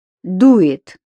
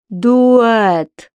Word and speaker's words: дует: Дует?» Она этот глагол как-то смешно произносила, вроде через «э» — «дуэт».